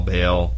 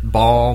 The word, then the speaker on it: Baal: Baal. Either one. I heard it Baal my whole life, and then when I got to college, really? They told me it was Baal.